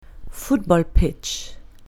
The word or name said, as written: football pitch